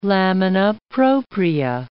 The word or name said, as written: lamina propria